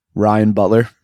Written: Butler

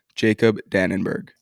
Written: Jacob Dannenberg

